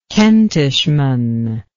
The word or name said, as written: Kentishman